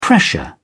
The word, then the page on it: pressure: British English